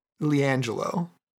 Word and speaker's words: LiAngelo: Is that how you say his name? Gello? Jello? – LiAngelo. – LiAngelo.